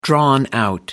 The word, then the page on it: drawn out: American English